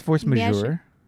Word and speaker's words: Force Majeure: What did you think of Force Majeure? Is that how you say it? – I think it's Force Majeure. – Majeure. Okay. Whoops.